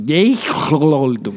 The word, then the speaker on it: Jeff Goldblum: Jeff Goldblum. – Lo amamos. – Y como lo pronuncié perfecto. Jeff Goldblum.